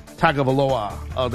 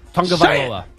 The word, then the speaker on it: Tagovailoa: You know, guys like Trevor Lawrence, quarterback of, you know, Clemson, or guys like Tua Tagovailoa. Did I say his name right? – Nope. – I always screw his name. Say his name. – It's okay. Tagovailoa.